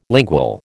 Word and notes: lingual: American English

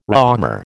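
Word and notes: Réaumur: American English

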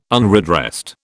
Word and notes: unredressed: British English

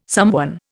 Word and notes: somewhen: Indian

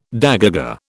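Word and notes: dégagé: British English

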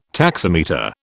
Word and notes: taximeter: British English

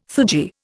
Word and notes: theurgy: Indian

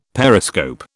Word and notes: periscope: British English

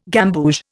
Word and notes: gamboge: Indian

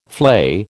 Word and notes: flay: American English

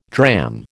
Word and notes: dram: American English

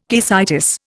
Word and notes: caecitis: Indian

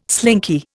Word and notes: slinky: Indian

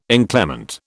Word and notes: inclement: British English

